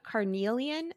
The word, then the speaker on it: carnelian: And so Lauren's favorite stone was carnelian. Am I saying that right, Lauren? – Yeah, at least that's what I call it.